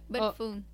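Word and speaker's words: Bích Phương: The third artist that I wanted to show to play a song from is Beach Puong. Do I pronounce that right? – Yep, Big Foon. – Big Foon. – Yeah. – That was so off. Like, my pronunciation was so off. – But you tried, though. Don't worry about it. – Big Puong. – Yeah, Big Foon.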